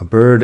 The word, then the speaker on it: bird: A bird in the hand is worth two in the bush. Fíjate también en la pronunciación de bird, que no es um, con la i larga como si fuera español.